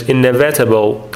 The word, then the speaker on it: inevitable: اون تلفظ لغت اولی که خوندم، چون بچه‌ها اینو اشتباه میخونن، براتون توی لغت‌ها نوشتم. بچه‌ها اینو خیلی مدل‌های مختلف میخونن، تلفظش pronunciation اون استرسش روی ای هستش، inevitable در حقیقت اینجوری خونده میشه به معنی جبران ناپذیر.